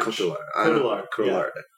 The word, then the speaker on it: Cotillard: Cotillard. Yeah. – Cotillard.